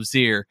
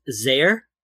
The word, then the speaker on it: Zier: And I didn't get that one either, because when I first visited the first town, I pronounced it as Zare, like to rhyme with there instead of to rhyme with here.